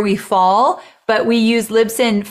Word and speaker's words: Libsyn: We use Liz, how do you say it? Libsyn? Yeah, Libsyn. – Oh, Libsyn.